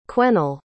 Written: quennel

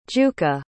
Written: jukka

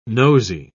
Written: nosy

